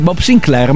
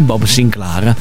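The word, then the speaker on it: Bob Sinclar: Non si dice Bob Sinclair, ma è Bob Sinclair. Mi raccomando. – Ah, perché è francese.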